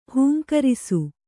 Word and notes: ಹೂಂಕರಿಸು: hūmkarisu

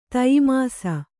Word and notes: ತಯಿಮಾಸ: Kannada

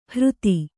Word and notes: ಹೃತಿ: hřti

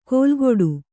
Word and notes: ಕೋಲ್ಗೊಡು: kannada; kōlgoḍu

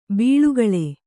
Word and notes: ಬಳಿಗೊಳ್: Kannada

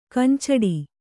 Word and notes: ಕಂಚಡಿ: kannada

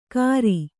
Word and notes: ಕಾರಿ: kannada

